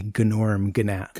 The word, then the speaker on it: Gnorm Gnat: Norm spelt with a G, Gnorm Gnat.